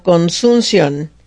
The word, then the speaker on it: consunción: Dice, en el siglo, a principios del siglo XX se llamaba consumo o consunción. Consunción, con uh-huh. acento en la O, consunción.